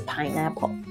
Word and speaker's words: pineapple: 基本上你会看到芒果，芒果的英文就是 mango，mango，还有凤梨 pineapple，pineapple，pineapple，虽然有一个苹果在里面，但是念的时候你一定要注意这个重音是在 pine 上面，所以呢听起来 pine 比较高，pineapple 不要念成 pineapple，pineapple，pineapple 不是 pineapple，注意一下 pineapple。